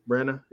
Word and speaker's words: Branagh: Directed by Kenneth Branagh, I probably butchered his name. Branagh, yeah. You probably got that right.